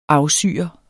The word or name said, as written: AFSYRET